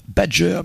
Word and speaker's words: Bdgr: On a commencé du côté de Newcastle avec le groupe Badger, mais ça s'écrit B-D-G-R.